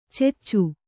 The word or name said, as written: ಚೆಚ್ಚು